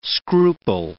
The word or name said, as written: scruple